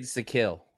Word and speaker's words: Zakelj: Nick uh Nick uh Zacho uh, Zacho. How you say it? Zacho. – Nick Zakelj. – Zakelj. Nick Zakelj. Zakelj. the kill.